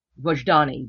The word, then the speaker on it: Vojdani: Can you say his name again? – Aristo, A-R-I-S-T-O, Vajdani, V-O-J-D-A-N-I. – Okay.